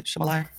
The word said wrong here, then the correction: Shalomia: His name that he goes by now is Shalomia Bowers, but he was born as Christman Bowers.